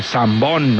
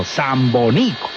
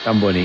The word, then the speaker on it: Zamboní: Y entonces, ese Zamboní es muy caucano para que la gente lo pronuncie bien. Ayer le estaban diciendo Zamboní. Sí. Otros, Zambón, no, Zamboní. – Zamboní. – Con el acento. – Los que somos de la región.